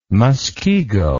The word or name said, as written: Muskego